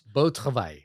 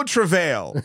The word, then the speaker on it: Beau Travail: Well, this prompt got me to watch your blind spot movie, which I still can't pronounce. – Beau Travail. – Beau Travail? – Yeah, which translates to good work. – Okay, I was like, Beau Travail. – Travail.